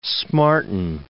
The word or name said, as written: smarten